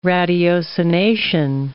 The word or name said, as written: ratiocination